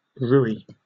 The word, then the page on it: rouille: British English